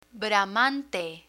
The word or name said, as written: bramante